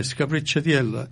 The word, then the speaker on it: Scapricciatiello: Yeah, Scapricciatiello.